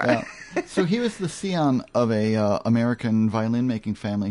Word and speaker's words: scion: how do we pronounce the word S-C-I-O-N? – Oh, do you want me to give it a try? People say Scion, but it's not. – I think it's Sion.